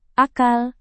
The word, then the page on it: akal: indonesian